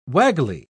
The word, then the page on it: waggly: British English